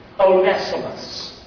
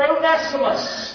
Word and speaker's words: Onesimus: But he pleads for a man named Onesimus. Or if you're from the South, one Simus. But the proper way to say it is Onesimus.